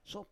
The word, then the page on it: sop: Roon